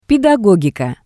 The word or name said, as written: pedagogika